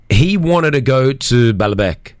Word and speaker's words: Baalbek: Yeah, he said it correctly. – Yeah, Baalbek. – Baalbek.